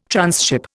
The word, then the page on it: tranship: Indian